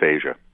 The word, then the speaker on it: A'ja: And it's pronounced what? – It's pronounced Asia.